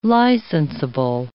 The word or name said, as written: licensable